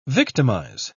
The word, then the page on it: victimise: British English